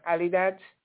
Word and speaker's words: Adidas: You know it as Adidas. It's a German company, Adidas. It's pronounced Adidas.